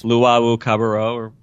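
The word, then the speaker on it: Luwawu-Cabarrot: Luwawu Cabarro, There you go.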